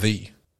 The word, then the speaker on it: ye: which is apparently just pronounced the Y-E. It's just pronounced the, apparently. – The. – As in the word the.